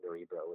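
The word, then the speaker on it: Nørrebro: It's Nerebro's in the neighborhood of Nerebro, which is in Danish. It's like Nerebro. It's like the strange. You know, O with a slash through it, which is Scandinavian pronunciation.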